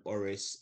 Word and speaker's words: Oris: So my LinkedIn is Oris Ikomi, which is O-R-I-S, and surname is spelled I-K-O-M-I.